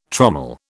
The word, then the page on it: trommel: British English